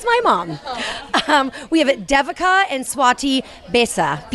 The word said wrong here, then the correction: Bizet: Bisa? – Bizet. – Bizet. Wow, I really butchered that one.